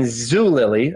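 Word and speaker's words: Zulily: How would you classify Zoot, Woolily, Zoot? Hold on. Let's just talk about my mispronunciation. I said Zoot and Woolily. I meant Woot and Zoolily, a Groupon, things of that nature.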